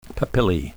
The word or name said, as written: papillae